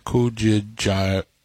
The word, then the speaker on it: Kujichagulia: And the other one, I cannot pronounce it, Kujichagulia.